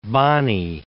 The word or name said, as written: Bonney